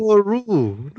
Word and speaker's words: Satoru: Satoru, not Satori.